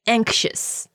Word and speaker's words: anxious: 对它的这个形容词叫 anxious, 就是更重会听到有那个 X 的音 anxious. – I'm giving a more North American pronunciation. British people, there would be more of a slight k in there. Anxious.